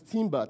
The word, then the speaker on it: timbre: Another word for that is timbre. Timbre is spelled T-I-M-B-R-E, timbre.